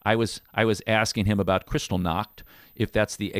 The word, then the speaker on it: Kristallnacht: You know, calling it Kristallnacht is perfectly acceptable.